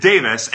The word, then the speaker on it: Tewes: And I guess that's another fun one because his last name is Tewes, T-E-W-E-S, but he pronounces it Davis, and Michaelis is a total shit show of how you pronounce and spell a name.